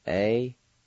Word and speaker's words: adehosga: Word number four is ash, and it's spelled K-O-S-D-U. Again, the spelling for the word ash, K-O-S-D-U. Word number five, ashamed. It's spelled A-D-E-H-O-S-G-A. Once again, the spelling for the word ashamed is A-D-E-H-O-S-G-A.